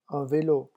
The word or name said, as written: un velo